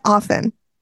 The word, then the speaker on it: often: He said often. – Yeah, that's how you say it. – No, it's not. Often. – Yes, it is. The often. T is silent.